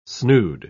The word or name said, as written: snood